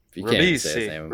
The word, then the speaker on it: Ribisi: Ribisi. Ribisi. – R-I-B-I-S-I.